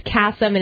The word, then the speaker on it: Kasem: Like, even though my dad, you know, has been out there, it's Kasem, and it's always with two S's or with a C.